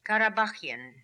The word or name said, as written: Karabaghian